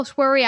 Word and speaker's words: Shwery: Uh, but everyone, go to Dr. Carol Shwery.